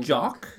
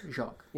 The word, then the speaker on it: Jacques: Actually, this is my biggest question about this whole thing, is how do you say his name? Is it Brian? – Brian Jacques. – Brian Jacques? – Jacques. – Yeah, he's French. French-Canadian. – So it's not Jacques. – Jacques.